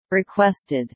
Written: requested

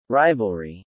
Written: rivalry

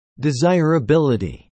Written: desirability

